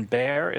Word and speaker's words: Barre: It's in Barre. Is that how you say it? B A R R E B A R R E. Yeah.